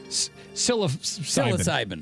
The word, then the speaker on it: psilocybin: And I do believe that the psilocybin.